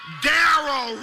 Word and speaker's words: Darrow: Darrow rhymes with arrow.